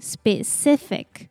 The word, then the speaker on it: specific: So the first part, the P sounds like B, and the E pronounces E. So it's spe, spe. And next, the C here pronounced like S. I pronounces E, so it's C, C. And the last part, the C here pronounces K, and we stress the second syllable, so it sounds like specific, specific.